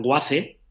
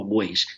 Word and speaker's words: Waze: Podéis pronunciarla WACE, o podéis pronunciarla como Waze. Se escribe WAZE.